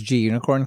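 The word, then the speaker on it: Gunicorn: Um, I'm pretty sure it's G unicorn because it's unicorn.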